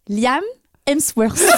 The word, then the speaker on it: Liam Hemsworth: Alors j'ai un petit problème de prononciation avec lui également. Je vais me lancer. Liam Hemsworth. Alors, c'est correct.